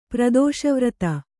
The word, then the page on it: ಪ್ರದೋಷವ್ರತ: Kannada